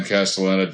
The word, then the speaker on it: Castellaneta: Well, Dan Castellaneta is have butchered his name. Sorry. – No, you got it. – Okay. – Yeah, Dan Castellaneta is like he's a voice actor with an elastic voice.